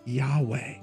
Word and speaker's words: Yahweh: Later, translators added vowels to make this YHWH pronounceable, and many people say God is Yahweh. Now, some other people say that if you were to sound it out, the YHWH would be pronounced Jehovah, like Yahowah, Jehovah.